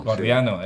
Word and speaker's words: gordiano: lleva al nudo jordiano, ¿lo he dicho bien? – Gordiano, eso. Gordiano.